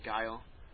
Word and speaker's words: Guile: I sat there and watched, and there was some guy playing it with Guile. That's how you pronounce it. I see people pronouncing the name. – We're going to go over that later. The actual... – calling him Gilly or whatever.